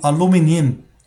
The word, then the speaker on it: aluminium: 好，最后还有一个词，我当时找了一个词也挺有意思的，也可以说明这个问题，就是美国人他那个吞音啊，比如说像铝合金这东西啊，美国人叫 aluminum。 aluminum，它那个 n i u m 呢就没有发 i 的音了，叫 aluminum。但是呢，澳式发音呢叫 aluminium，aluminium，这是有差别的。 aluminum 和 aluminium 是不一样的，所以大家去感受一下。